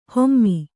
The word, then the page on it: ಹೊಮ್ಮಿ: hommi